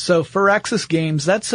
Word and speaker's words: Firaxis: Even though it's not A-X-I-S. Yeah, but it's pronounced Firaxis. Like fir tree.